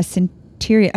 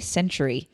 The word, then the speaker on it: centurion: When he had entered Capernaum, a centurion, I can never say that right. I always want to say century. Centurion.